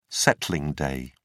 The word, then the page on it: settling day: British English